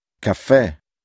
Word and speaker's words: caffè: Many words are stressed on the last syllable. These words always have a written accent on the last vowel. – Caffè.